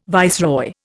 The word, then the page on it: viceroy: Indian